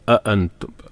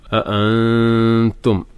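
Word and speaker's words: أأنتم: അഅൻതും ഹംസ പറഞ്ഞല്ലോ, ശ്രദ്ധിച്ച് ഉച്ചരിക്കണം. മലയാളത്തിൽ നമ്മൾ ആ പറയുന്ന ഉച്ചാരണമല്ല, അ കുറച്ചും കൂടിയും ഹൽക്കുന്നാണ് ശബ്ദം വരേണ്ടത്. അഅൻതും അഅൻതും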